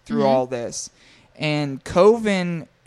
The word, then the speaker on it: Coven: Um, so Coven is spelled C O V E N, which we know as Coven. – Mm-hmm. Um, so Coven, but he pronounced it Coven.